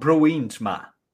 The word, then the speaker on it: Bruinsma: How do you pronounce his last name? – Bruins. – Bruins. – Almost. Bruins. – Bruins. – Ma. – Ma. Bruins Ma.